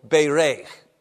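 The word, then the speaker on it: bereich (בָּרַךְ): Bay like in the, you know, a bay in the ocean, bay, and then rake like you're raking. But it's only one word, accent on the last syllable, "bereich." But it's not really a K like in rake. It's more like a "ch" as in Bach.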